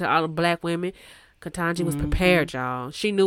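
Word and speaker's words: Ketanji: But listen, honestly, it's unfortunate what with what Katanji, say her name for me. – Katanji Brown Jackson.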